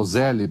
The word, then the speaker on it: Zelle: É, não vá para os Estados Unidos e fale para usar o Zelle, para não passar vergonha, que é Zell, tá? Que se pronuncia.